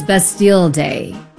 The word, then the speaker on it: Bastille: If you are wondering why the French national anthem is playing, it is Bastille Day. Yes, you do pronounce the L's in that.